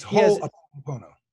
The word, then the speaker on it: Ho'oponopono: He has been most impressed and personally transformed by the Hawaiian forgiveness teaching of Ho'oponopono. – That's Ho'oponopono. – What is it? – Ho'oponopono.